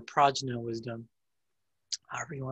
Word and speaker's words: prajna: Prajna is the better internal pronunciation, because you know, it can get confused with the other Indian version of prana, which is related to chi, the physical aspect of chi.